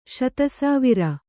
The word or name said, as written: ಶತಸಾವಿರ